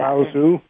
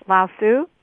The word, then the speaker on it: Lao Tzu: Lao Tzu. – Yes, Lao Tzu.